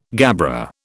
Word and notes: gabbro: British English

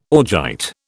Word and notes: augite: British English

